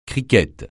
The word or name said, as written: cricket